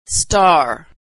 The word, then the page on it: star: American English